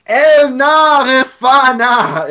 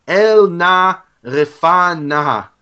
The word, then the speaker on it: El na refana: Is shouting out to Yehovah, saying, "El na refana." It's four syllables.